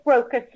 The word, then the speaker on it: Brocas: Go to the little search box, and you can just put in Joanne, J-O-A-N-N-E. Last name is Brocas. Yes. Am I pronouncing that right? – Yeah, it's Brocas. Yeah, Brocas.